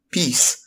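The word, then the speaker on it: PiS: And the Law and Justice Party is, in short, peace. But I vastly prefer your pronunciation. – So, which is piss.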